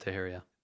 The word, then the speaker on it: Teixeira: Teixeira is pronounced Teixeira. – Teixeira? – Mm-hmm. – Okay. Well, now we know. You sure it's Teixeira? – Multiple people, yeah.